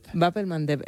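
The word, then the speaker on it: Bab el-Mandeb: ¿Cómo? – Bap el Mandeb, esa me la voy a apuntar. – Cuesta un poquito pronunciarlo, por eso en Occidente se dice el Mandeb.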